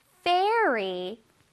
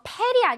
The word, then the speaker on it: fairy: Fairy, fairy, 좋아요. 이거 페리 아닙니다. 페리 아니고 fairy.